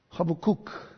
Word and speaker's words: Habakkuk: I want you to turn, if you will, to the prophecy of Habakkuk, actually, a little closer to the way you should pronounce it. Habakkuk, chapter 2, verses 18 through 20.